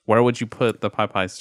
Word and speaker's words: Popeyes: And Frank is the only one that's had the Pie Pies. – Pie chicken. Pies? Why'd you say it like that? – Popeyes. – There you go.